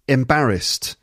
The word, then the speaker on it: embarrassed: Three syllables. Okay, and there's an ED ending there, ED, but it's not embarrassed, it's embarrassed. St at the end. Embarrassed. Embarrassed. That's three syllables, and it's the second syllable again.